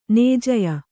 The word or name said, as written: ನೇಜ್ಯೆ